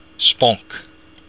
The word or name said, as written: sponc